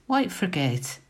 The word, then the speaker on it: Whitefriar: Here in Hull, we pronounce this Whitefriar, just one of Hull's many idiosyncratic pronunciations.